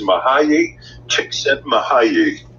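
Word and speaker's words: Mihály Csíkszentmihályi: And the way it is pronounced is Mahayi Csikszentmihalyi.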